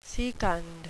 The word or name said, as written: secund